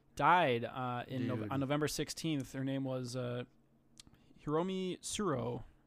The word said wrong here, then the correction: Tsuru: Yeah, we still have the English voice actor, but R.I.P. to Hiromi Suro. I did not pronounce that right. Tsuru. There we go.